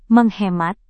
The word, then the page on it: menghemat: Indonesian